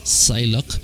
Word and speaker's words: Psylock: Y en su canal oficial de YouTube, Psylocke, se escribe P-S-Y-L-O-C-K, Psylocke, tienen